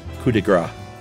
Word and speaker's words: coup de grâce: The Arctic Fox episode is the latest, and Josh said the phrase coup de grace, where he correctly left the P silent, but incorrectly said fat.